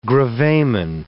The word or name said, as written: gravamen